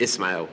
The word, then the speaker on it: Ismail: No, you put an H in it. It's no H. – Ismail, no H. – Ismail, yeah.